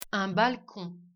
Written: une bande-annonce

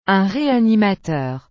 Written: un réanimateur

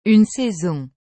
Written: une saison

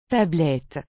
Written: Tablette

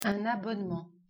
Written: abonnement

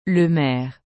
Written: le maire

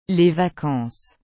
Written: les vacances

